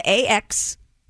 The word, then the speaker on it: axolotl: Please tell the pronunciation of the animal she's trying to say is axolotl. – Axolotl, okay.